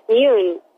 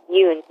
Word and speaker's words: Yun: Oh, well, you can either pretend that it's the U is two O's. It's a strong U. So just put like a silent E at the end or do whatever you have to do, but it's Yun, yes.